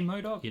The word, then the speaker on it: MODOK: Like, do you say MODOK? – You do.